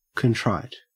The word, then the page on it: contrite: Australian English